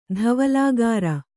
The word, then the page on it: ಧವಲಾಗಾರ: dhavalāgārfa; kannada